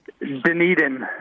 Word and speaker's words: Dunedin: How do you say your team name? – Dunedin.